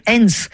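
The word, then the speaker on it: Hen's: Musique jusqu'à présent dans Afrique Soir avec une immersion dans la musique de l'artiste camerounais Hens.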